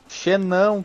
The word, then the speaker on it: Xenon: Pronunciou errado. É Xino? Xenão, cara. Xenão.